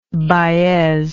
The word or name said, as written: baez